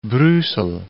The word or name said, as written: Brussel